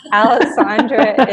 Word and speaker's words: Alessandra: Alessandra is spelled A-L-E-S-S-A-N-D-R-A.